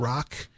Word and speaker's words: Roch: Now, his name is spelled R-O-C-H, but pronounced Rock.